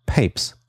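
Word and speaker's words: Pepys: Jahrhundert den Pub als das Herz Englands bezeichnet hat, wurde zu seinen Lebzeiten vermutlich Papes ausgesprochen. Dann kam es, wie Hörer Ludwig mit Verweis auf eine Arbeit von Heiko Arns von 2011 so schön schreibt, zu einer Lautverschiebung von A zu I.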